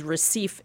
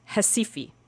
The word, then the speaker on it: Recife: There, I discovered that the R at the beginning of a word in Brazil is pronounced like an H. So actually, the city that we all call Rio is pronounced Rio and the city that I thought was Recife is pronounced Hesifi.